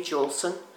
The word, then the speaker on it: Julson: Maggie Juleson, J-U-L-S-O-N.